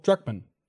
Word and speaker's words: Druckmann: Druckerman? – What's his name? – Is it Druckerman? – No, Druckmann? Neil... – Druckmann. – Druckmann. Yeah.